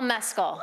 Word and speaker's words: Mescal: Well, you have to think about it. It's an Irish. It's not going to be Mescal. Mescal. It's not Irish. You got to say it like if you were saying an Irish accent.